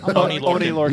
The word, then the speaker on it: Oney Lorcan: Oney Lorcan. Am I saying that right? – Yeah, Oney Lorcan.